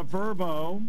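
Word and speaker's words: Vrbo: The Verbo, that's how you pronounce it, Fiesta Bowl, Liberty against Oregon.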